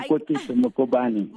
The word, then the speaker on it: Koketso Mokubane: I'm Koketso Mokubane.